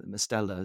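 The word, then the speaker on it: Mestalla: Away out in the Mestallas, Mestella is how you pronounce it.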